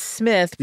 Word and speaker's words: Smyth: His last name is S-M-Y-T-H, and so it's Smith, pronounced yeah.